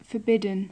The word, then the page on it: forbidden: English